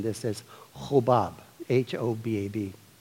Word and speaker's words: Hobab: And it could, in Hebrew, it would be pronounced Kavo.